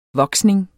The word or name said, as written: voksning